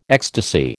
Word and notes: ecstasy: American English

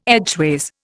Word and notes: edgeways: Indian

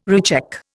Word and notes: recheck: Indian

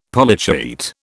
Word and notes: Polychaete: British English

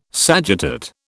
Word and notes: sagittate: British English

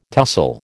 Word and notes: tussle: American English

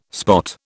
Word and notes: spot: British English

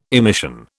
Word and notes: emission: British English

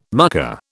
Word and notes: mucker: British English

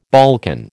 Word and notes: Balkan: American English

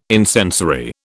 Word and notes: incensory: British English

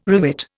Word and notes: rivet: Indian

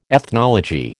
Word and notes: ethnology: American English

